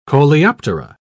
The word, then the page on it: coleoptera: British English